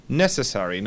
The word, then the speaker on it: necessary: Entonces ario va a ser ari o eri. Se pronuncia ari o eri cerrando la boca. Por ejemplo, necesario, que termina en ario, sería... Necessary. Nos vamos a llevar el acento una sílaba o dos, dependiendo de la palabra, adelante.